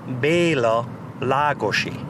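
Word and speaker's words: Béla Lugosi: Bela Lugosi, in my language, in Hungarian, is pronounced Bela Lagosi.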